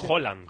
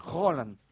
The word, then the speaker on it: Haaland: Es Haaland. – Haaland. Como si dijeras un queso, ¿no? – ¿Sabe qué pasa el martes?